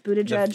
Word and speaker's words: Buttigieg: It is pronounced Buttigieg, guy. – By the way. I did not know it was pronounced Buttigieg forever.